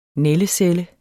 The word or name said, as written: nældecelle